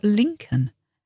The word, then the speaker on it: Lincoln: And what about Lincoln? L-I-N-C-O-L-N. Lincoln is the city and Lincolnshire is the county. So all of these have a silent L.